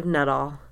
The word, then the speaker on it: Nuttall: Detective Nuttall. – How do you spell that? – N-U-T. Nut. A-L-L. All. Nuttall.